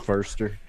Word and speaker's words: Foster: Forster. – Yeah, Jodie Foster. That's how you say it.